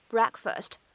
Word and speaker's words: breakfast: 那么打破禁食的第一顿饭呢，通常来说是早饭，所以呢，早饭我们称之为是 breakfast。你也可以把它理解成为是 break 这样一个词义加上 fast 得到的。只不过呢，在写在一起以后，哎，发音的变化呢是很大的，需要注意一下。